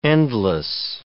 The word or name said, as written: endless